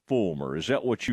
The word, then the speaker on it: Folmar: All right, Aaron, it's spelled F-O-L-M-A-R, but every time I listen to an interview, I hear Fulmer. So I'm kind of going to go with Ryan Fulmer.